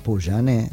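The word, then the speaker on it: Pouyanné: Hubo una reunión a solas entre el titular de esta compañía francesa, espero pronunciarlo bien, Patrick Poujané. – Poujané, sí.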